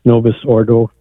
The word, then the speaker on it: Novus Ordo: And I believe it's because they think it's more reverent than the Novus Ordo, if I pronounce that right.